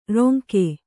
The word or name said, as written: ರಂಗ